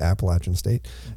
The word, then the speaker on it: Appalachian State: And that is how you say it, Appalachian State.